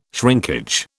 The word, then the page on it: shrinkage: British English